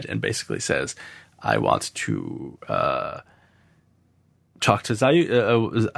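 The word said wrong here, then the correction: Ziyal: I can never pronounce her name. Zayel? – Ziel.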